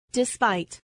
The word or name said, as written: despite